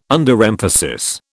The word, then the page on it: Underemphasis: British English